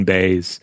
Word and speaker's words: baize: It's green, so it's Green Baize. And apparently the archaic version of the spelling in English was like B-A-Y-S. So it's pronounced, more or less like that.